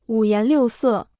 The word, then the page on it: 五颜六色: wǔ yán liù sè